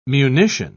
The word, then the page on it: munition: British English